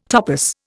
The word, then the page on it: topos: Indian